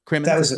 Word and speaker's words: Crimmins: About Barry Cummins. Mm-hmm. Is that how you pronounce it? Is that the last name? Yeah. – Yeah. Crimmins, I believe.